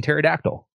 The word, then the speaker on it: pterodactyl: It's like P is in pterodactyl.